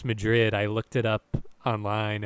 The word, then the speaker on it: Madrid: Which like they come up to the sign and they see it says, it looks like it says Madrid and it's pronounced Madrid, and John Travolta's like, Spain?